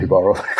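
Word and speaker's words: Jíbaro: I think it's pronounced Jibaro. Jibaro. Yeah, because he's Spanish, right?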